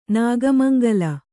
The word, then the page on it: ನಾಗಮಂಗಲ: Kannada